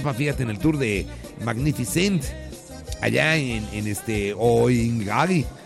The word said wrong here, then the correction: Qinghai: en China. – ¿Dónde? – Qinghai. – Oh, siempre le cambias. – Oh, pues, ¿cómo lo pronuncias tú? – Qinghai.